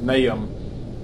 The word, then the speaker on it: Nahum: I even had to look up to make sure I was going to pronounce it right, because I think for my whole Christian life I've always said Nahum, which would be, I guess, the Heaton County version of it, but it's, you get a pronunciation, it's basically pronounced Nahum pretty consistently.